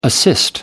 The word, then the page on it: assist: American English